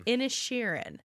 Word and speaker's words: Inisherin: Inisherin.